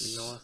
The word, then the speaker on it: Mignola: Is that how you say his name? – Yes, Mignola? The great Mike Mignola.